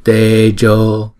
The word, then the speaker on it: Tejo: Chữ E và chữ O là đều là hai nhịp hết, cho nên chúng ta đọc chữ này là Tejo, Tejo. Chữ J này đọc là J, chứ không phải là J, không phải là Tejo mà là Tejo, Tejo.